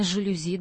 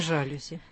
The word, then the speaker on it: жалюзи: Ну, конечно, во французском с ударением все просто. Там ударение всегда падает на последний слог. И поэтому правильно «жалюзи», достаточно помнить, что слово это из французского, чтобы правильно поставить ударение. – А не «жалюзи».